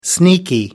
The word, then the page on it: sneaky: American English